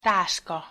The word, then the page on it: taska: Hungarian